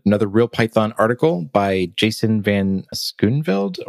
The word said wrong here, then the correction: Schooneveld: or um, sorry, I need to ask him how to pronounce his name properly. – It's, a, uh, it's Schooneveld. – Schooneveld, thank you.